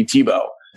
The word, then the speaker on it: Thibault: So I would kind of flip it, but my mom's from France, and in France, they'll put 8 letters in a word and only pronounce like 3 of them. – That's great. – And in my case, the 3 letters the sounds that would come out of the way that you spell my name, yeah, would be Thibault.